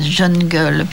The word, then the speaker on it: jungle: faut paraît-il prononcer à l'anglaise jungle, mais c'est pire.